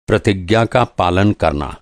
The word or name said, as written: प्रतिज्ञा